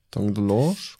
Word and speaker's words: Tom DeLonge: Zu 18% Prozent hättest du noch kriegen können äh, Tom DeLonge von Blink 182. – Mhm. – Spricht man das eigentlich so aus? Tom DeLonge oder Tom DeLong oder weiß ich nicht. – Tom DeLonge.